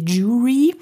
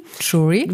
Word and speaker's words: Jury: Ja, oder auch der Franz hat uns nochmal drauf aufmerksam gemacht, dass wir einige Sachen falsch aussprechen, wie zum Beispiel Jury. – Jury. – Würden wir mit also Tisch sagen, Jury, das ist falsch, wir müssen Jury sagen.